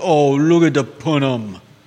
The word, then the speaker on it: punim: The Yiddish word of the day is punim.